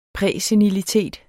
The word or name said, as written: præsenilitet